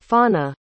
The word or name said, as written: fawna